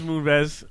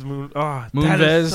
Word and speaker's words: Moonves: Yeah, no, it's Moonves. It's Moonves.